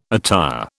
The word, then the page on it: attire: British English